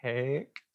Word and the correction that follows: Haeck: Rhymes with cake, so hake.